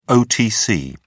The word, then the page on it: otc: British English